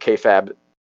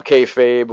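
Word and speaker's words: kayfabe: And it was fun to go throughout his career, even though at this stage in professional wrestling, everything like KFAB. I don't even, is that how you say it? – Yeah. – K-Fab, K-Fabe, whatever.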